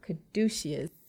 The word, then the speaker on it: caduceus: There is the caduceus. – Caduceus staff. That's right. – I said it correctly.